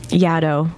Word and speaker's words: Yaddo: Like the you, when you say you've been to Yaddo that mm. rhymes with shadow.